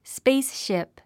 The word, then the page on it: spaceship: spáce shìp; スペースシップ